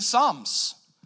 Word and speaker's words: psalms: We sing psalms, like literally the book of Psalms. It starts with a P. It's not psalms. It's a silent P, and it's the songbook of Israel.